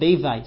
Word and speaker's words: Bevi: He's called Rav Bevi, sometimes pronounced Rav Bibi.